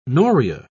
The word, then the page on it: noria: British English